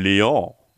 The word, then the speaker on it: Léon: Und den spricht man Léon aus.